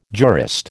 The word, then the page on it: jurist: American English